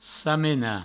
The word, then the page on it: samenar: samena